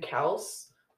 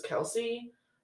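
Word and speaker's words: Kelce: I think they technically pronounce their name Kels, but everyone says Kelsey.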